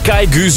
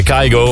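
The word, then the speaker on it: Kygo: Verse muziekje van Kaigu, zo moet je het eigenlijk uitspreken. Het is Noors, maar wij zeggen Kaigo.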